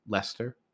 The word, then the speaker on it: Leicester: Like, if we go north from there, you'll eventually get to a town that's spelled Leicester, and it's called Lester.